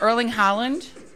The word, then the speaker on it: Erling Haaland: She showed me a video of Erling Haaland.